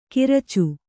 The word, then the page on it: ಕಿರಚು: kiracu